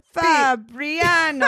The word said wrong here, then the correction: Fabiano: Fabiano. – Oh my God, she said it wrong again.